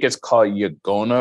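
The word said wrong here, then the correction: yaqona: or something like that. Y-A-G-O-N-A. I may have mispronounced it, but that's what it's. Oh, it's Yangona, I think is how they pronounce it.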